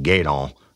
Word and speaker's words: Gueydan: at the Barn Lounge in Gaydon, Louisiana. And that's spelled G-U-E-Y-D-A-N, Louisiana. Now, let me tell you about Gaydon.